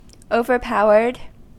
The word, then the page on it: overpowered: American English